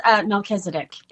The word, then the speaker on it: Melchizedek: Did I pronounce that correct? Michelisit Priesthood? – Yes, it is Melchizedek Priesthood.